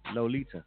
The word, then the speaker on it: Loletadior: L-O-L-E-T-A-D-I-O-R. I-O-R. And she just broke it down and said it's Lolita. L-O-L-E-T-A-D-I-O-R. But it's pronounced Lolita. What in the hell is the I-O-R silent? So the I-O-R is just A.